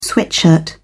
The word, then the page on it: sweatshirt: British English